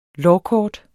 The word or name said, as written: lårkort